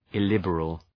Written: illiberal

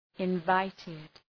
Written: invited

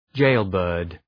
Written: jailbird

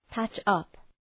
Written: patch up

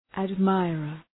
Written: admirer

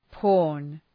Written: porn

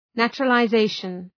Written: naturalization

